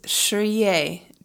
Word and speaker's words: Shriyei: This week's mantra is Shriye, just one word, Shriye. It's spelled S-H-R-I-Y-E-I, Shriye.